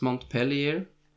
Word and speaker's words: Montpellier: and those are mont pilar uh, and i have heard i I pronounced that very wrong in France, in French. Uh, but I'm gonna say it like it's spelled, and it's Montpellier.